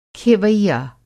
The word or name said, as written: खेवैया